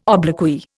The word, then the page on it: obloquy: Indian